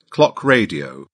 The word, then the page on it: clock radio: British English